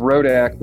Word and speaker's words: Rodak: It's Rodak, but everyone does Rodak. So like, it's Rodak like Kodak.